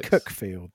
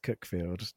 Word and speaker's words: Cuckfield: Yeah, which is in Cuckfield. – Yeah, pronounced Cookfield, thank you very much. – You keep telling yourself that, the people of Sussex. – It's definitely pronounced Cookfield. No one pronounces it Cuckfield.